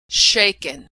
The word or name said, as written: shaken